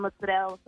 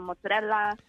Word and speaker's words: mozzarella: Some pronounce it without the A at the end, mozzarella, some mozzarella. – Oh. – It depends on the region. Like in southern Italy, we put the A on the end of it.